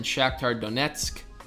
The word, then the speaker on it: Shakhtar Donetsk: In the Europa League, Sevilla, Man U, Inter Milan, and Shakhtar Donetsk.